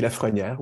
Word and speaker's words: Lafrenière: Aujourd'hui, c'est Daniel Lafrenière, je prononce bien ton nom. Oui, Lafrenière, oui, tout à fait.